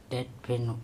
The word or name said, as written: TeTpheno